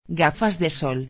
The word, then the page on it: gafas de sol: espanhol